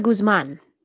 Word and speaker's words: Guzmán: Oh, it's actually Mónica Guzmán.